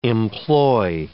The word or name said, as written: employ